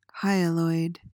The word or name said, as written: hyaloid